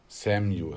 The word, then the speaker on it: Samju: Juwelier oder äh, Samju, Samju, wie spricht man das aus? – Samju ist das jetzt.